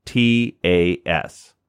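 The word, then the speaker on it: Yossistas: But it's spelled Y-O-S-S-I-S YOSTAS. So it's like Yas, Y-O-S, and then Sistas with an A-S at the end. Or Yassi, Y-O-S-S-I, and then Stas, S-T-A-S.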